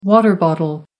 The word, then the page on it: water bottle: American English